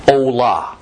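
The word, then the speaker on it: Olah: Usually in the Bible, this particular Hebrew word that we're dealing with has an O and an A vowel sound added to give us the spoken word Ola.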